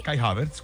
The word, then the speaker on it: Kai Havertz: Lo hizo Kai Havertz. ¿Sí se pronuncia así? – Kai Havertz, correcto.